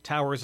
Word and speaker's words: TTWWRRSS: Towers, of course, if you are a Hamilton music aficionado, you know it's spelt T-T-W-W-R-R-S-S. So when you see that, Towers may not be what you think immediately, but that's what it is.